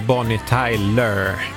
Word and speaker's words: Bonnie Tyler: Älskar uttalshjälpen från musikproducenterna Bonnie med i T-A-J-L-Ö-R, alltså Bonnie Tyler.